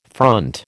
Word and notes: front: American English

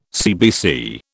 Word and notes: CBC: British English

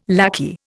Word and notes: lackey: Indian

